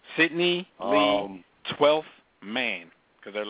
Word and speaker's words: Cydnee: It's pronounced Sydney. – It's still spelled. – But they spelled stupid. – It. Here's how they spelled it. – Either way you pronounce it. – Yeah. – Yeah. – C-Y-D-N-E-E, pronounced Sydney.